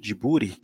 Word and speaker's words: Ghibli: Cara, falando um pouco sobre o estudo Ghibli, pronúncia em japonês Jiburi, foi fundado em 15 de julho de 85 pelos diretores Hayao Miyazaki, Isao Takahata e pelo produtor Toshio Suzuki.